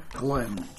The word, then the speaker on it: Glynn: Yeah, Michael Glynn, G-L-Y-N-N.